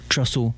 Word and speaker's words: Trussell: The drummer and the co-founder, Ron Bookie Smith, said, let's name the group Trussell. And the way he pronounced it, it ended up being spelled that way, T-R-U-S-S-E-L.